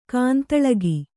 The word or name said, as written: ಗೊಂದಲಿಗ